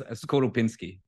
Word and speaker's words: Skorupinski: Yes, Skorupinski.